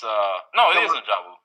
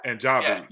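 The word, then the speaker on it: N'Jobu: It is N'Jabu. – Killmonger's dad? – N'Jabu, yeah. So I've been saying it wrong.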